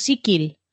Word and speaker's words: Sicil: Como no nos están viendo, pero sí nos están escuchando, voy a recordar a los oyentes que cuando decimos Sikil, ese sonido k es una C, se escribe con C, o sea, se escribiría Sicil, sí. Se pronuncia Sikil,